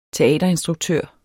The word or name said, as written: teaterinstruktør